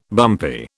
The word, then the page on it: bumpy: British English